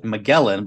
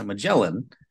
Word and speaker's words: Magellan: And it was about, as they pronounce it, Magellan, but as we pronounce it, Magellan.